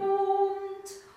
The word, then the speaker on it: Mund: An Herz und Mund. – Und auch und Mund. Das ist zwar ein D, wird aber als T ausgesprochen, sonst versteht es kein Mensch.